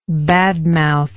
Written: badmouth

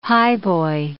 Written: highboy

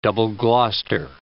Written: Double Gloucester

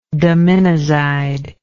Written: daminozide

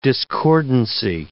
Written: discordancy

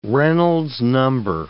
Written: Reynolds number

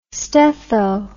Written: stetho

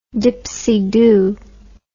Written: dipsydoo

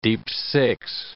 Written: deep-six

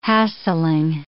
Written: hassling

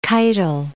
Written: Keitel

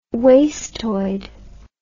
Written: wastoid

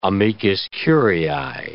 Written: amicus curiae

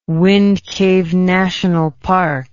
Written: Wind Cave National Park

